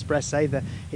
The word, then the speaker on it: Bressay: This particular one plies Lerwick Harbour and goes between Lerwick and the island of Bressay, and in fact, or Bressa as it's sometimes pronounced in Shetland.